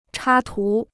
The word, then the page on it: 插图: chā tú